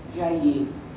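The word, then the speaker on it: Jahier: Allora, un altro riferimento letterario che voglio fare è quello a un testo abbastanza famoso di Pietro Jaillet. Io lo pronuncio alla francese perché la famiglia è di origine francese.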